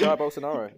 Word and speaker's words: Jair Bolsonaro: Jair Bolsonaro. – Jair Bolsonaro. – No, you got it. You got it. Jair Bolsonaro. Yeah, Jair Bolsonaro. – I'm just getting antsy. – Jair Bolsonaro.